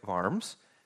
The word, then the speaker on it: Worms: It's pronounced, it's spelled like worms, but it's pronounced Worms, in Worms, Germany.